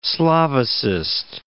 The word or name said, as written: Slavicist